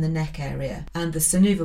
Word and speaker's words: Sunuva: I really like the funky children's sun clothing brand called Sunuva, which is spelt S-U-N-U-V-A, Sun U-V-A.